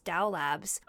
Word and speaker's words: Dao Labs: If you go to Dow Labs, and it's spelled DAOLABS, Dow Labs.